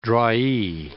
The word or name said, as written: drawee